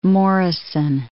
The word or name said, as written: Morrison Cave